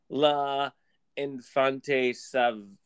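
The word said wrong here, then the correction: L'Enfant Sauvage: I think it's the brown one that it looks like. – Le Font Sauvage. – Sauvage. Okay, yeah.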